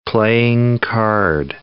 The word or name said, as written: playing card